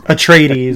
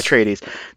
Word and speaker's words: Atreides: Atreides. – Yeah, Atreides.